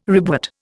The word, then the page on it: ribwort: Indian